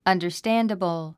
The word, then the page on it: understandable: ʌ̀ndə r stǽndəbl; アンダァスタンダブル